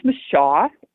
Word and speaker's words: Mashah: The name is Mashah. It's spelled M-A-S-H-A-H, and it actually means to draw out.